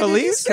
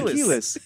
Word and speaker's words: Kelis: Kelis? – Kelis.